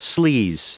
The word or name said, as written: sleaze